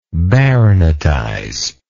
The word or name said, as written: baronetize